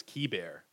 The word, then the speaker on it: Keibert: Keibert, I think it's Keibert, like key, like door key, and then bear, as in, you know, grizzly bear.